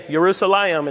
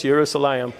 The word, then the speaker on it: Yerushalayim: I'm just saying, Yerushalayim, it's not Jerusalem, it's Yerushalayim.